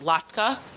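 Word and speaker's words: Lakhta: And it also talks about Project, I think it's called, pronounced Lackta. Project Lackta.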